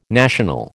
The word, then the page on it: national: American English